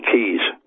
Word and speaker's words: cay: I think they said there were 700 islands and about 2,000 or 3,000 keys. Spelled C-A-Y, but it's pronounced key.